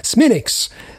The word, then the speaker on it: Smithwick's: Smithwicks, c'est la prononciation parce qu'évidemment nous sommes en Irlande. Donc, euh, faut pas sortir sa très belle prononciation euh, anglaise à ce moment-là parce que vous n'êtes carrément pas compris.